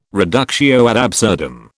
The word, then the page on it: reductio ad absurdum: British English